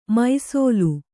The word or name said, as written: ಮೈಸೋಲು